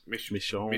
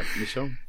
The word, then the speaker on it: Michonne: Walking Dead, I'm not sure how you pronounce this. – Michonne. – Michonne. is that right? – Yeah, Michonne.